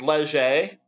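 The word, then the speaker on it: Léger: First off, I love that you called it Leisure. There, it's Canadian. It's probably got some French to it. – It's Leger. I think is how they pronounce it.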